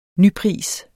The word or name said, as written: nypris